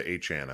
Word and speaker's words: Achane: Yeah, he asked for it to be changed to Achane, I believe.